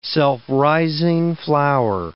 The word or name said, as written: self-rising flour